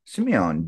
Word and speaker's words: Simeone: So hopefully here we will be having John Simeone joining us.